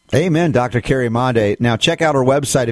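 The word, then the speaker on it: Madej: I tell people, remember, make Mondi.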